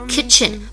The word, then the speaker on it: kitchen: There are some chairs in 可以连读，chairs in the kitchen kitchen 中间的 t 不要读出来，直接失去 kitchen 不是失去的这个单词本来这个 t 就不发音，t c h 本来就要发一个 ch 的音，这个我们要记住啊，kitchen。